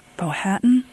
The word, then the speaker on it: Powhatan: You are a Cherokee Creek, and I hope I'm pronouncing this right, Powhatan? – Yes.